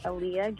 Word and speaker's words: Aliah: Yeah, so you can follow me at Aaliyah Guerra. So it's not spelled like the other Aaliyahs, the singer, it's just A-L-I-A-H.